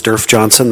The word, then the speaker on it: Derf Johnson: Mr. Chairman, members of the committee, my name is Derf Johnson. That's spelled D-E-R-F-J-O-H-N-S-O-N.